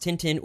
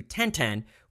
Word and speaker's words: Tintin: Ralph Silva said that in French, it isn't pronounced Tintin or Tonton. It is closer to Tintin with the N nasally and partially swallowed.